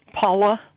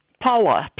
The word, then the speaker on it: Paula: Paula Moore. – Okay, can you spell that, ma'am? – Paula, P-A-U-L-A, Moore, M-O-O-R-E.